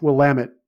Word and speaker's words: Willamette: And it's pronounced Willamette, damn it.